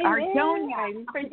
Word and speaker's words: Ordonia: And speaking of our guest coach, please welcome Francesca O'Donna Hollingsworth. I said it wrong again. I'm sorry. – It's okay. Totally cool. Hi, Ordonia. – Ordonia, yeah. Francesca Ordonia Hollingsworth, yeah.